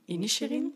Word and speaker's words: Inisherin: Maar ik denk dat het The Banshees of Inisherin... – Ja, zo heb je dat ook nu gehoord, heel vaak. Inisherin.